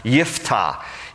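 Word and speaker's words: Yiftah (יפתח): His name in Hebrew is actually pronounced Yiftah.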